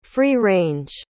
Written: free-range

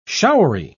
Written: showery

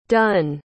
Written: dunne

